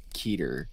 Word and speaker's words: Keter: sure this is the highest class classification of danger, where it goes, it, it goes safe Euclid Keter.